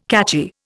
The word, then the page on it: catchy: Indian